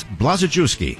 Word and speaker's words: Blazejewski: But now I must turn the gavel over to the next Speaker of the House, Chris Blazejewski. Now, on day one, let's get his name right. It's Blazejewski. Four syllables, a lot of letters.